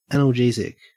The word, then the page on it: analgesic: Australian English